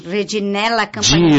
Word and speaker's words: Reginella Campagnola: Obviamente, começando com Vivo na Bela Jornada, que é o tema da Festa da Uva, terminando com Reginella Campagnola. É assim que é pronunciado? – Reginella Campagnola. – Ai, Reginella Campagnola.